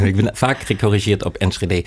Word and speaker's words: Enschede: Ik spreek Enschede altijd verkeerd, dus hoe zeg ik dat goed? Enschede? – Enschede.